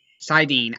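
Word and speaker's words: saidin: Whereas Sidar has to be controlled. Sidine, I keep saying it wrong tonight.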